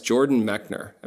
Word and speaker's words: Jordan Mechner: We have on the podcast Jordan Mechner.